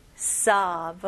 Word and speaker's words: sob: 근데 여기서는 입술은 사용하지 않고 그냥 턱만 벌리는 느낌으로 보세요. sob. sob, okay?